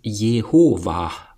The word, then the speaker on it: Jehova: Jahrhundert und deuten an, dass es Jehova ausgesprochen werden sollte, mit einem stillen H am Ende.